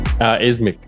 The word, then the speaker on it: YZMIKH: Ismic. – Ismic. – The Y is pronounced as an I.